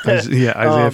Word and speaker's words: Isaiah: They no longer have the Isaiah 53:5. I think I'm saying that right. – Right. Yeah. Yep. Isaiah 53:5.